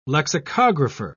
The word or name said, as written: lexicographer